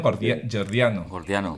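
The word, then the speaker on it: gordiano: sí, dime, hablabas de la disolución de los nudos antes, sí, y esto sí, me sí, lleva al nudo jordiano, ¿lo he dicho bien? Jordiano.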